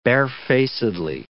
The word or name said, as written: barefacedly